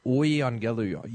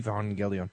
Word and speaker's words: euangelion: Yeah, people forgot that the U was pronounced like a U and started pronouncing it like a V. So euangelion, EU became EV, evangelion.